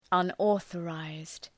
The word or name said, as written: unauthorized